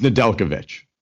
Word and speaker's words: Nedeljkovic: Nedeljkovic. It's Nedeljkovic.